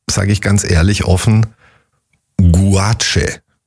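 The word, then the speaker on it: gouache: G-U-A-C-H-E. – G-O-U-A-C-H. – Guache. – Guache. – Guache. – So spricht man es aus.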